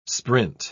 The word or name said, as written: sprint